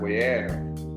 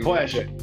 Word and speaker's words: Voyager: Cara, o, o, o Voyager, Voyager, não, esqueci já como é que fala o nome, mas beleza, eu sou meio. – Voyager.